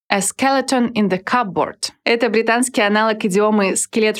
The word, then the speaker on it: cupboard: Отмечу, что в слове «cupboard» есть буква «p», но она не произносится. «Cupboard».